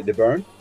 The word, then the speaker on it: Byrne: Te lo paso si hubiera ido por el camino de Byrne, lo dije bien, Kawaii. Ok, perfecto. Sí, me moví la cabeza. – Hasta está emocionado así de... – Exactamente. Lo dijo bien.